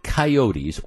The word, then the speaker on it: coyote: There is an ongoing problem in southeastern Wisconsin with coyotes, or if you prefer coyote, it depends. They both pronunciations are acceptable.